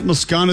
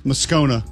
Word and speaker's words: Moscona: Matt Moscona is going to be with us. Moscona, excuse me. I got to get that right.